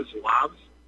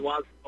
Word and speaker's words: Zouaves: There were also a group, and I'm sure you know about them, they were called the Zouaves. And the Zouaves, yes. – Pardon me? – Yes, some people pronounce it the Zouave. Pardon me for interrupting, but yes, go ahead. – Zouaves.